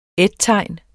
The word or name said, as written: ettegn